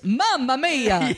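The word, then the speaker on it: Mamamia: Once this gets up on the internet, there's going to be articles all over Mamma Mia about this. Oh, did you hear the little Dum Dum Club? – You pronounced it wrong. It's Mamma Mia, that Italian website.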